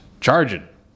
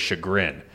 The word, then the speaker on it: chagrin: No, that's not how you say it. That's how I used to say it because we used to do vocab tests in high school, and that was the way I remembered it because I was like, oh. – I thought you were going to say, like, charging your phone. – No. So the actual word is chagrin. Oh. And I realized that I was just saying it wrong because I must be illiterate, and I couldn't.